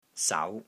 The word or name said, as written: SAU2